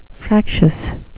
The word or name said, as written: fractious